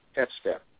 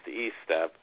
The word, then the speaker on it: Estep: Yes, actually it's English. It's pronounced E-step, but it's a common mistake. – I'm sorry. – Yeah, Eric, it's E-step. Yes, long E. Sorry. Mm-hmm. But most of the time it's pronounced S-step.